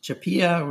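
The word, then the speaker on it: CHAPEA: Yeah, CHPEA, we pronounce it, stands for Crew Performance and Exploration Analog.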